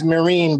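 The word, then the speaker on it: Myrin: Aren't we supposed to pronounce it Arden Mirren? – Yeah. – Like the Marines? Arden Mirren. – Mirren. – Like Helen Mirren. – Really difficult because it really does look like Mirren. – Yeah. It looks like it should be Mirren. – Yeah. – The proper pronunciation is Mirren,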